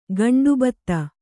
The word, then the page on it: ಗಂಡುಭತ್ತ: kannada